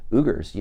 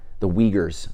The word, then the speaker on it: Uyghurs: You have 2 million Uyghurs. You know that. – It's pronounced Uyghurs.